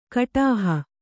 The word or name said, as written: ಕುಡುಹಿ